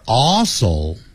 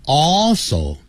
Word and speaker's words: also: We are also。 Also，你看，al 有一个 l，所以 all 舌尖抵上去。 Also，你不能懒惰。 Also，如果你念成 also，这舌尖没有抵上去就不对。